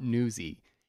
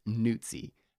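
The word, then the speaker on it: Nuzzi: It is not Newsy. It is Newtsy, like pizza. It's got like the TS sound.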